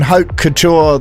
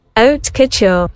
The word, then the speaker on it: haute couture: Is Christian Dior and Haute Couture the same thing? – So this is how you say it, ready? – Haute Couture.